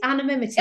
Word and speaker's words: anonymity: people really like that animate. How do you say that? – Anonymity. Yep.